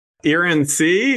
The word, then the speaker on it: Irancy: Irancy is how you pronounce that region of France.